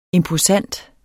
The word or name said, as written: imposant